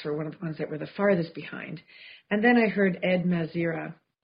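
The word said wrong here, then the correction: Mazria: Mazaria, thank you.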